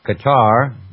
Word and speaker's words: Qatar: Qatar, and this is another one of these little sovereign wealth funds, you know, Qatar, they call it Qatar. Well, it's Qatar, it's the way it's been pronounced for years.